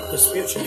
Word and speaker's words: Vespucci: Vespucci, right? – Vespucci, yeah.